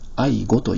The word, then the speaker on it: 愛語: 皆様は愛語という言葉をお聞きになられたことはありますか？愛情の愛に国語の語と書いて愛語と読みます。